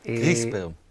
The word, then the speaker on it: CRISPR: CRISPR. – כן, CRISPR. זה SPR, אבל משתמשים, מבטאים את זה כאילו שיש E לפני R, וה-Cas9 זה יחד עם האנזים, זה המנגנון הזה שעובד ביחד.